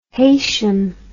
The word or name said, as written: heysham